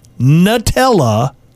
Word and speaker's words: Nutella: I've just found out that a lot of Americans think Nutella is pronounced Nutella. Guys, it's literally made from nuts. Nutella. – Oh. – There I said it. – I say Nutella.